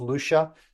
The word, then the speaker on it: Lusha: If you look at the spelling, it looks like it's pronounced Lusha, but it's really pronounced Lucia. Lucia is Eastern European for grandmother.